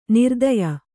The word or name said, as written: ನೃತ್ಯ